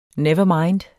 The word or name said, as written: never mind